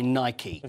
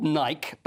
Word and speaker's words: Nike: So this is 27 years that Tiger Woods has been sponsored by Nike. – Is it really? – Often called Nike, but Nike, who is the Greek god of victory. So that's the pronunciation.